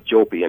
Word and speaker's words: Jouppi: I'm Jim Jopi, and it's spelled Jouppi, and that's, you know, then they can Google around and see what I've been dealing with.